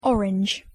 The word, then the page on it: orange: English